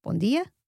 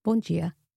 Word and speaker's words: bom dia: In this case, we have a D sound in Portugal when D is followed by an E or an I, but you may remember Rafael pronounced these as a J sound, and I say bom dia and boa tarde, but he says bom dia and boa tarde.